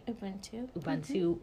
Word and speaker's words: Ubuntu: Ubuntu Wellness Window.